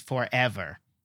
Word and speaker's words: forever: It's forever, not forever. It's hard to even say that. Or forever. It's forever. So the emphasized syllable is that middle one. It's the eh, right? It's for, not emphasized. Eh, emphasized, and ver, not emphasized.